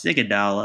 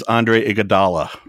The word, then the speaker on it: Iguodala: No, it's Iguodala. I screwed that up.